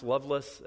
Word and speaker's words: Lovelace: It's Lovelace, L-A-C-E, but it's pronounced Loveless.